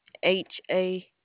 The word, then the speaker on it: Hal: HAL.